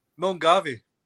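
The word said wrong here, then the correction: Milngavie: That's what I said. – It's Mungai.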